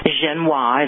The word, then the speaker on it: Genois: George Genois is how we pronounce his name.